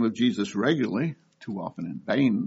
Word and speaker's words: Jesus: They started to call Jesus Alou, J. Alou. Because although his name in Spanish is pronounced Jesus, it's spelled J-E-S-U-S. And as we know in English, that's pronounced Jesus.